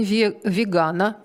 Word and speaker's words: вегана: 1 ноября, среда, Международный день вегана. Ударение официально закреплено именно на «а». Вы удивитесь, но это так.